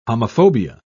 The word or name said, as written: homophobia